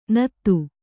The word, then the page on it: ನಾದು: kannada